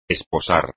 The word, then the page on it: esposar: espagnol